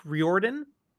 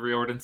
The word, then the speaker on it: Riordan: Fantasy novels written by Rick Riordan.